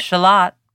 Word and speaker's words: Shalott: When I picture the Lady of Shalott, though, I think of her going out onto a lake and in the lake is, are we saying it right? Is it Shalott? – Lady of Shalott.